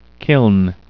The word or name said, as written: kiln